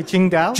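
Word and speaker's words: Tsingtao: How now, Xingdao? – Yes, I think you got the pronunciation a little wrong. – But it's close. We'll say Qingdao.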